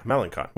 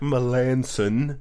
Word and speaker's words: Melancon: So there is a relief pitcher who, if you were to look at his name, would look like Mark Melancon. Melancon. Would you not agree? – Yeah, that is how it looks like it's spelled. – Looks like that. That's not how you say it.